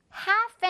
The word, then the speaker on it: half: half an hour. half가 아니라 half, L 유령 친구.